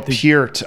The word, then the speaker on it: Peart: Neil Peart, I believe, is the correct, whatever, Canadian pronunciation.